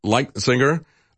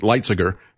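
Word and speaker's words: Lighthizer: Trade Representative Robert Lightsinger. I guess is the way he pronounces it, Lightsinger, to look at reentering the TPP negotiations.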